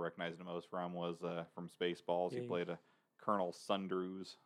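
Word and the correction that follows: Sandurz: Am I pronouncing that right, Brent? – Sanders. Colonel Sanders. – Is it Sanders? Yes. Okay, well, the way that they spell it is odd. It's spelled Sundruz.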